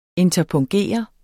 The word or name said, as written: interpunktere